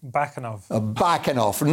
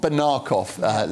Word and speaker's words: Bakanov: Bakanov, not Banarkov.